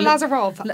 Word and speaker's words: Lazaro: And then she asks Liz what she knows about the mysterious artist Paul Lazaro. – Yeah, so or Lazarov, as that name is actually sometimes pronounced.